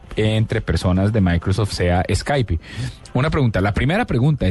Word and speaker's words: Skype: y en algunos casos la E al final en inglés suena como I, entonces Skype, pero la, las raíces de la empresa no son americanas, son de Estonia.